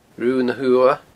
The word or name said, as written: Rubha na h-Uamha